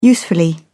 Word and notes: usefully: British English

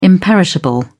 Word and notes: imperishable: British English

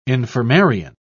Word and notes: infirmarian: British English

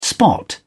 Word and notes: spot: British English

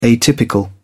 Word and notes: atypical: British English

